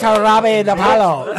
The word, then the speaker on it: Jarabe de Palo: pronounced Jarabe de Palo.